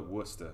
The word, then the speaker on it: Worcester: But it's spelled like Worcester. – Yeah. – Why do you fucking call it Worcester? – Don't ask me.